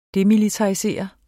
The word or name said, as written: DEMILITARISEREDE